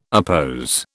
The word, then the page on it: oppose: British English